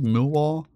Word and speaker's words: Millwall: Anyway, recently, there's this football fan from Millwall. Am I saying that correctly? Millwall? – Millwall, yeah.